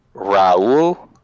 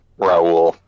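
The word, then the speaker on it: Rahul: but I guess I want to say it's pronounced Raul, or well, so it's R-A-H-U-L.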